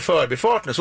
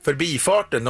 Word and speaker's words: förbifarten: Eh, då kan det ju vara så att det heter förbi men heter förbifarten. Så skulle jag anse det. Men de som då säger förbifarten, de går ju på, de hämtar liksom uttalet ifrån förbi in i den här sammansättningen och accepterar inte att du kan få en annan tryckbetoning då i, i, en, i, en, i en längre sammansättning.